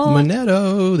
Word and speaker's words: Menneto: Last name I can't remember or pronounce. – Menneto. – There we go. – There it is.